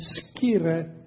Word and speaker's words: scire: Con, which means with, and scire, that's the Latin pronunciation of S-C-I-R-E, which means to know.